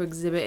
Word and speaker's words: exhibit: I guess, what is a strange thing to exhibit in a museum? – Strange thing to exhibit in a museum. – Exhibit. – Some people don't pronounce the H. Because you're not supposed to.